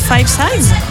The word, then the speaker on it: Five Size: Jérôme, du groupe Five Size, j'imagine que ça se prononce comme ça.